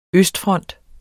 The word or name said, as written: østfront